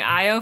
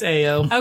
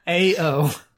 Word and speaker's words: Ao: Okay, I'm so sorry, but I thought you were mispronouncing Io for a second. – Nope, it's Ao. – Okay, no, that guy. – It is Ao.